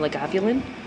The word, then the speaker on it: Lagavulin: It was the 16-year-old, it was 16-year-old Islay from Lagavulin.